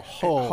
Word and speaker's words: Hull: How do you spell that? I don't know. – H-U-L-L. Hull.